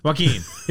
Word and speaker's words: Joaquin: Joaquin Phoenix? How do you say it? – Joaquin.